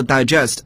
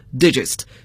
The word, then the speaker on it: digest: 但这个文摘呢就不能读作 digest 而要读作 digest, 所以拼写相同可是发音词性各不相同。